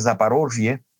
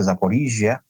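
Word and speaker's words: Zaporizhzhia: que es Zaporozhye, en ucraniano se dice Zaporizhia,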